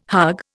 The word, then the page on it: hug: Indian